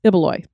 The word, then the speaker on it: Ibaloy: I do want to point out that Ibaloy is pronounced as Ibaloy, so we said it wrong.